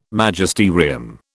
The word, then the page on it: Magisterium: British English